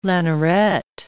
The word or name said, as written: lanneret